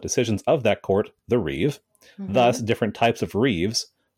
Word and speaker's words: reeve: In Anglo-Saxon England, the reeve, R-E-E-V-E, I don't know if you pronounce the third E or not, but I think it's reeve, not revie or revy.